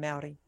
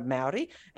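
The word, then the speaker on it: Māori: My daughter has had some friends who, and has some friends who are Maori. And every time I would say Maori, she'd go, no, you've got to say it like moldy cheese.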